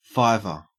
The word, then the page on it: fiver: Australian English